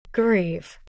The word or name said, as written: grieve